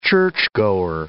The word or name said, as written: churchgoer